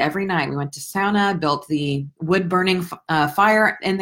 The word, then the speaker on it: sauna: That's how it's pronounced, by the way. It's the only Finnish word in the English dictionary, sauna, not sauna.